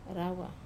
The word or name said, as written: rawa